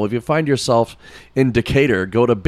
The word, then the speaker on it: Decatur: Big Bob Gibson's Barbecue in, what is it, Decatur? Yeah, that's how you pronounce it.